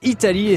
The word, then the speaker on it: Eataly: Ça s'appelle Italie et ça s'écrit E-A-T-A-L-Y.